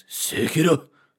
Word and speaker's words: Sekiro: I believe I if you're gonna say the word, you need to really say it right, and it's Sekiro.